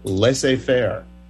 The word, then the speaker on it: laissez-faire: Z is silent in the English expression laissez-faire.